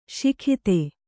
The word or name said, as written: ಸೈಕತ